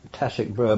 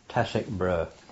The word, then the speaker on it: Tasek Bera: Tasik Brur, big lake district, big swamp area. – How do you spell it? – T-A-S-E-K-B-E-R-A. Tasik Brur.